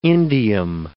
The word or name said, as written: indium